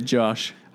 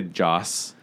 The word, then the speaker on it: Joss: You always say Josh. – I try to say Joss, but I slur it, so yeah.